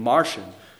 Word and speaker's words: Marcion: All the way back in the second century, there was a Gnostic leader named Marcion, not to be confused with a little green man. His name is spelled M-A-R-C-I-O-N.